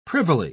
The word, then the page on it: privily: English; British English